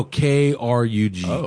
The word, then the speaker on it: Krug: You want to be drinking Krug? – Krug? – Yeah. Or C R U? – No, K R U G. Krug.